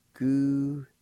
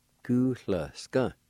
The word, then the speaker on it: Goo-hla-a-s-ga: Goo-hla-a-s-ga. Goo-hla-a-s-ga. Hu-la-ska. And sometimes you might hear it pronounced like this. A sound being omitted, it'll sound like this. Gu-la-ska. Sometimes it's pronounced like that.